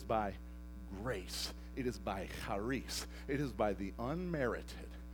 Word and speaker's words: charis: And make sure you put the cha on it. You know what I'm saying? Because it's a Hebrew term. Charis, right? And it's spelled C-H-A-R-I-S. Now, most people say it's charis, but it's not. It's pronounced charis.